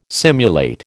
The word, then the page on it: simulate: American English